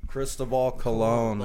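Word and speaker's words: Cristóbal Colón: Cristobal Colon. – Like Bartel Colon, but with the Cristobal.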